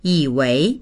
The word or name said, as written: yi3wei2